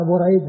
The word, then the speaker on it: بريد: يعني هذا يزيد بفتح الياء وهذا بريد بضم الباء, فالرسم واحد, وهذا اللي يسمونه المؤتلف والمختلف, يسمى في علم الصلاح المؤتلف والمختلف, بأن يتفق الرسم والشكل ويختلف النطق ويختلف النطق.